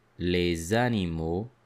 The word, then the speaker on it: les animaux: Notice how it is pronounced. Les animaux. Les animaux. You will hear that the letter S is pronounced as a Z.